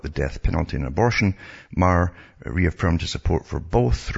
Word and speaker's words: Maher: I think it's Maher. I generally pronounce it Maher or Meyer, but it's Bill Maher it's pronounced.